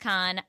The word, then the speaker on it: Cahen: Her name is Molly Kahn, M-O-L-L-Y, but her last name is spelled C-A-H-E-N, but it's pronounced Kahn.